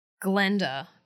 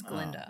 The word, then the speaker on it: Glinda: It's like people saying Glenda, the good witch, when it's Glinda. Oh, it's Glinda, guys.